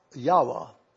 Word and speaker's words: Yahweh: These records indicate that, in all likelihood, the name should be pronounced Yahweh. YAHWEH.